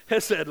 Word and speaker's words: chesed: Yeah, chesed love. You got to get a little throaty on the pronunciation.